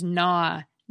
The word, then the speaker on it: gnaw: Yet another consonant cluster that English doesn't have anymore is GN, which is the source of the silent G in words such as gnaw, gnat, and gnarly.